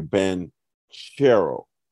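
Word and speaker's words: Banchero: And he said the CH is like cherry. And he said he asked, was a security guard at Chase. He said he asked Paolo that. And he said, yes, that is the correct pronunciation. So it's like cherry, Bonchero.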